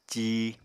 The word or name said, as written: cí